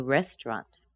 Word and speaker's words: restaurant: Of course, that restaurant looks like three syllables, but it's actually pronounced as two, and the stress is on the first syllable. We ate hot soup from the new restaurant.